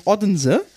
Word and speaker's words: Odense: Der hat geschrieben O-D-E-N-S-E-N. Ich sag immer Odense. – Odense oder so. – Ja. – Weißt du, wie ausgesprochen wird am Ende? – Unse. – Hälfte des Wortes ist stumm.